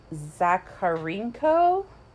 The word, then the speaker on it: Zakharenko: And I'm going to butcher this because it's like a Russian last name. Zakharenko? – Good. – Sure.